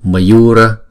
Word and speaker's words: Mayura: Chữ này thì chữ Yu này là hai nhịp, còn chữ Ma và chữ Ra là một nhịp. Mayura, Mayura, không? Mayura.